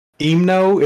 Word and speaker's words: Himno: Well, it's pronounced Himno. It's like H-I-M-N-O.